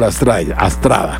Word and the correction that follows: Estrada: Estrada. – Estrada, sí. Michael Estrada. No se escribe igual, ¿no? No se escribe igual, es con E en el caso de del apellido del jugador, pero suena igual.